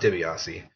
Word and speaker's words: DiBiase: Fun fact, I actually asked John DiBiase, it's pronounced DiBiase, if we should do a joint category and just do solo artist of the year, and he made fun of me.